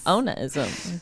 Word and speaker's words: onanism: So, let's talk about masturbation or onanism. I said it wrong. You told me how to say it and I still said it wrong. Say it again.